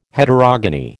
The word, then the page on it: Heterogony: American English